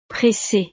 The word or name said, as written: presse